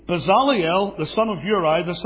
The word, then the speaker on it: Bezaleel: Most people say Bezaleel. That's okay. If you don't mind being wrong, that's fine. But Bezaleel is how you say it.